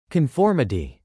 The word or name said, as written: conformity